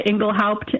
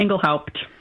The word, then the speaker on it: Engelhaupt: No, my family, we always said it. It's a, you know, it's a German name, and we always pronounced pretty much all the letters in there, Engelhaupt.